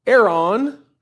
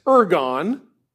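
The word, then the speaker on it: eron (ἔργον): The Greek term there for work, it's pronounced eron. We would in English read it as ergon.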